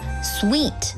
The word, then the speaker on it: suite: 如果是多人旅行或者是全家出游，你还可以考虑订上一间套房 suite，注意发音是 suite S-U-I-T-E suite。